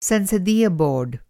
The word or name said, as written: बोर्ड